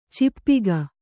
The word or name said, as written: ಚಾಪಕ